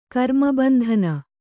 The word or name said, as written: ಕರ್ಮಬಂಧನ